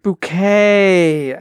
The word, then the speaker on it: Bucket: In the 80s in England, there was a very popular show about this woman whose name was Hyacinth Bucket, and she always pronounced it bouquet, you know, and she was clearly lower middle class, aspiring to be upper middle class or aristocratic.